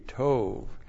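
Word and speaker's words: tov (טוֹב): T long O B, tov.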